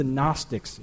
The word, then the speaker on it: Gnostics: This group were known as the Gnostics. It's spelled G-N-O-S-T-I-C. It's a Greek word for knowledge, gnosis, silent G.